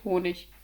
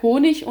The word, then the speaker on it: Honig: König, mit, nee, König, König, Honig. Ich glaube, ich sage Honig und König. – Ah ja, richtig ist jeweils CH.